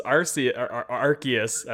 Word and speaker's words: Arceus: And then Pokemon Legends Arceus, as I was informed it is now pronounced, is coming out January 28th.